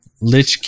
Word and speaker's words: Lich: The upcoming expansion was revealed in a live stream on Tuesday, April 19th, which was also used to announce World of Warcraft Lich King Classic. Did I say that right? Or is it Leech King? – I don't know. – Lich King Classic. I said it right the first time.